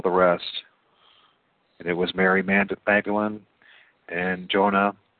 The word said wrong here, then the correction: Joanna: and, did I say Jonah? Joanna? I think that's not pronounced right. Joanna or Jonah? – Yes, Joanna.